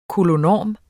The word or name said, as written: koloenorm